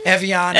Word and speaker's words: Evian: Do you remember Avion? Have you seen Avion? – Evian. – Evian.